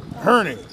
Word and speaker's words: Herney: It looks like Henry. – Herney.